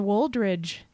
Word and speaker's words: Woldridge: This is episode 509, where we are interviewing the esteemed author, Trisha Woldridge. And yes, there's a D in that name. Make sure you pronounce it sirs and madams.